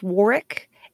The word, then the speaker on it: Warwick: The star's name is pronounced Warwick. Anytime you see a W in the middle of a British word, disregard it.